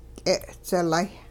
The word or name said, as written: k'e'dzilay